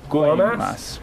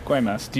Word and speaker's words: Guaymas: Guaymas. Guaymas.